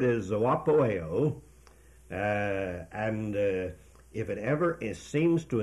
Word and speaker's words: zoopoieo: That word is zoopoeo. I'll spell that for these careful students. That spells Z-O-O-P-O-I-E-O. Now that first letter O there, Z-O-O, the first O is the long O, omega, and the last O is omega.